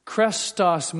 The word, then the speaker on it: chrestos: And we would spell it C-H-R-E-S-T-O-S. Krestos means pleasant. But krestos sounds very much like the Greek word for Messiah, Christos, C-H-R-I-S. T-O-S.